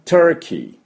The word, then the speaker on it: Turkey: Turkey、 Turkey、これが、トルコという意味になります。はい、で、 Turkey をですね、 T-U-R-K-E-Y、車のキーのキー、 K-E-Y、 K-E-Y ですね。で、 Turn の T-U-R です。だから、 Chuars の音です。だからね、 Turkey、 Chuars で Turkey、 Turkey って言わなければいけません。